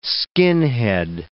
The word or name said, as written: skinhead